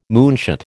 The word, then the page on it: moonshot: American English